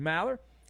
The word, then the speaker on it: Maller: New DraftKings customers, sign up with code Maller. How do you spell that, man? I'll tell you, M-A-L-L-E-R.